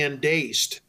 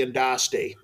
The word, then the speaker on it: Andaste: I think it's pronounced the Endaste, though everybody calls it the Endaste. A-N-D-A-S-T-E.